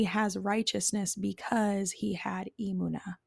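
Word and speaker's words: Emuna (אמונה): So I have to, it's Emuna. Emuna.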